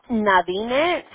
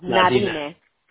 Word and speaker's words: Nadine: Es Nadine. Es que no hay un modo como pronunciarlo en español. Se escribe N como de Nancy, A D de David, I de India, N de Nancy, E de Eduardo. Nadine.